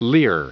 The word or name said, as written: leer